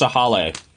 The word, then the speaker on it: Sahale: And it's pronounced Sahale. S-A-H-A-L-E.